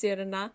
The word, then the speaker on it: Sirena: As a mermaid called S-I-R-E-N-A, but pronounced Sirana.